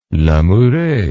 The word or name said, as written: lamoureux